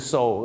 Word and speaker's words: sew: so